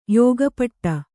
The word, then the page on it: ಯೋಗಪಟ್ಟ: yōga paṭṭa